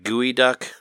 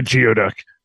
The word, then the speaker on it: geoduck: There was a funny joke in there where Seattle has like gooey duck or something that it's called. They say they pronounce it like that. And it's funny because the waitress says, like, spells it out and says that you would spell it like gooey duck because it looks like gooey duck or whatever. – It looks like geoduck.